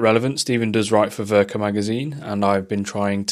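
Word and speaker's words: Werke: I'm going to tell you to go and look at Verka magazine. It's W-E-R-K-E, but it's pronounced Verka,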